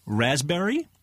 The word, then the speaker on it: raspberry: K-N-E-E-L, Neil. – Raspberry, answer. – Silent letters. – Yes, silent letters. Raspberry is the silent S. – P. – Well, P. Sorry. – Silent P, right?